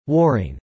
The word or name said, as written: warring